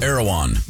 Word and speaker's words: Erewhon: There is a grocery chain on the West Coast called Erewhon. It's spelled E-R-E-W-H-O-N.